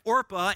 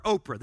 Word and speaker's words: Orpah: That's her name, Orpah, in her birth certificate. They didn't know how to pronounce it or say it. They called her Oprah.